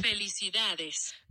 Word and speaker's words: felicidades: Felicidades.